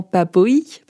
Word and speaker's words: Papay: Et donc, du coup, au début de l'émission, je vous ai dit que mon nom de famille, c'était Papaye. – Ah oui, vous, nous deviez une explication. Exactement. et en fait, ça se prononce normalement Papoï, parce que mon papa était hongrois.